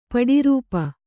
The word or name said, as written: ಪಡಿರೂಪು